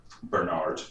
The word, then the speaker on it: Bernard: Also, because it's a Rose and Bernard. That's how they say it in this show.